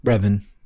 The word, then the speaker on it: Revan: Like, I thought, is it Darth, it looks like it's spelled Raven, but isn't it Darth Raven or something like that? – Revan. – Yeah. – It's R-E-V-A-N.